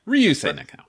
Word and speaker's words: Ryusei Nakao: Ryosei Nakao, okay. I almost said Horikawa. I'm like, no, that's Vegeta. But I was hoping... – I might have said it wrong. It might be Ryusei. Yeah, Ryusei Nakao.